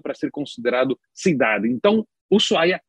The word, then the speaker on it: Ushuaia: cuja capital é Ushuaia, é, que não se pronuncia Ushuaia, como muitas pessoas dizem, mas sim Ushuaia, porque o H é mudo e Ushuaia é uma palavra no idioma jagante, o idioma dos indígenas que existiam ali naquela, naquela região.